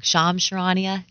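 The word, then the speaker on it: Shams Charania: Sham Charania.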